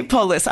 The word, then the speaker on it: Poulos: Okay, Poulos.